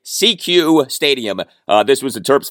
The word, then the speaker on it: SECU: CQ is spelled S-E-C-U, all caps.